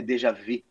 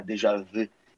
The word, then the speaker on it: déjà vu: É, a, a, a, a pronúncia correta é déjà vu, né? Que é francês. Então é déjà vu.